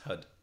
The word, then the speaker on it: Har: That uses the Har mantra, pronounced HUD. – HUD.